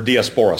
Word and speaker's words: diaspora: Does anybody know what the word diaspora means? Or diaspora, some people pronounce it differently.